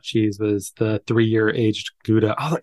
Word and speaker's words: Gouda: I mean, I'm like, I always say like I'm American, I say Gouda, but Gouda is like, I mean, a Dutch person would say that's a terrible accent, but yeah, it's Gouda is the more like the correct pronunciation. But yes, I grew up saying Gouda and I still say Gouda.